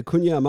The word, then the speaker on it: Qunja: Now, are you going with Kunja or Kunya? – Yes, that's exactly right. The Kunya monks, Q U N J A, the Kunja monks.